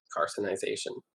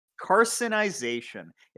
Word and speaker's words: carcinization: If carcinogenation, or whatever it's called, is a real thing, I guess... How is it pronounced? – Carcinization. – Carcinization.